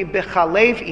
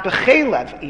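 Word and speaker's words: B'Chalev (בחלב): the pronunciation of the Torah, Lo Tevashel Gedi B'Chalev Imo, and not B'Chelev Imo.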